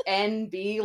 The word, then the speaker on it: NB: So we touched upon non-binary as an identifier or NB, which is pronounced NB, like the letter N and the letter N and the letter B,